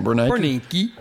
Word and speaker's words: Bernanke: Did I say his name? Bernanke. – Good enough.